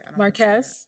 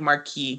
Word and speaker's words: Marquez: Marquez. – Yeah, Marquez Houston. I want to call him like Marquis because I used to know a Marquis.